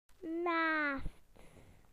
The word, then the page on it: next: British English